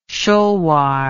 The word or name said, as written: shulwar